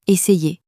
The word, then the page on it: essayer: French